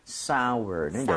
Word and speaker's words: sour: So, S-O-U-R인데, sour가 아니라 sour는 sour. – 여전히 그렇게 아우어처럼 발음해 주시면 되겠습니다.